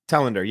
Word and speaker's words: Telander: Zack, am I pronouncing your last name right? Telander. – Telander.